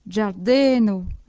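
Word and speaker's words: ĝardeno: G, avec un accent circonflexe, se prononce comme un D suivi d'un J, giardino, un jardin.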